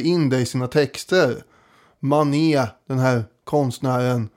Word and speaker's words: Manet: Men Manet, det är ju rätt uttalat i alla fall.